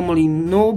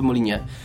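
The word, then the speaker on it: Molyneux: a Anglicy mówią na niego Molinu, bo oni czytają generalnie wszystko po swojemu, a francuska wymowa tego nazwiska to byłoby Molinie.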